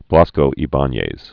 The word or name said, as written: Blasco Ibanez, Vicente